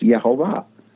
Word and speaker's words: Yehovah: I feel more confident today than when I wrote the book that at least what we know today is that in the Hebrew manuscripts, his name is pronounced Yehovah.